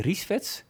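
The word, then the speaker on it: Rissveds: Jullie hadden een mooi verhaal over Jenny Riesvets.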